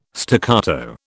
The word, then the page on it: staccato: British English